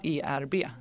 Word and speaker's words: Herb: För jag har sagt med tydligt H, men det är alltså inte Herb Essentials. – Alltså Herb är ju egentligen ett slangord för marijuana. Alltså ERB.